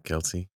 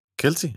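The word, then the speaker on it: Kelce: que lo dijo Kels. – Kels, no, Kelsey.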